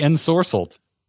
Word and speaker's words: Ensorcelled: And the I don't know how to say this. Ensorcelled. Sorcelid? – The Ensorcelled.